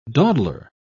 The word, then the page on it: dawdler: British English